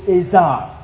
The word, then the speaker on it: Ezer: That is the word in the Hebrew, Ezer. E-Z-E-R, pronounced A-Y-Z-E-R, Ezer.